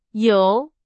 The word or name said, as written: 由